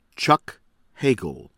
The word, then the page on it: Chuck Hagel: American English